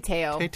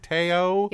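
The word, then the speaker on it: Te'o: I say Teo. – Teo.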